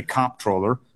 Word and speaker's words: comptroller: I happen to say comptroller. I hit it hard on the first syllable, and some people say controller.